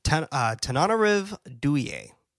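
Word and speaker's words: Tananarive Due: This is an excerpt from, and it's kind of long, so bear with me, but this is an excerpt by Tananarive Duye. I want to say I said her name correctly. – I think you got it.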